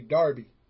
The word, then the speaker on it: Derby: Guy by Voices from Dayton, the Pipers, the Vipers, the Snakes, Golden Ghost out of a, now it's, it looks like it's spelled Derby, but in England they say Darby.